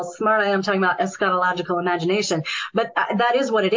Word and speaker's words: eschatological imagination: So one of the terms you bring up, and I'm probably going to butcher it, but I'd love for you to speak on it for just a minute, is the eschatological imagination. – Yes. – Can you kind of leave, and then we'll talk about all the places you can be found, but I'd love to just kind of end on that because it's all part of it. – Absolutely. And you did a beautiful job pronouncing it.